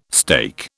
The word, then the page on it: steak: British English